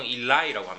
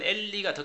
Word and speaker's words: 엘리: 뭐 영어 쓰는 사람들은 이걸 보통 일라이라고 합니다. 일라이 일라이 라마 사박타니라고 하는데 사실상 원래 발음은 엘리가 더 가까워요.